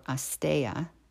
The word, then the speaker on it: asteya: The yama, which is one of the ethics of yoga, of non-stealing, or asteya, as it's pronounced in Sanskrit, can also be a consideration for keeping the virtue of silence.